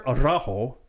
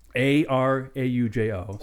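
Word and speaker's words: Araújo: The composer, I apologize ahead of time for butchering this name, but Oscar Araujo, Araujo. So. – Close enough. – Right. – You rolled an R in there.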